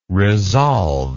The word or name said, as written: resolved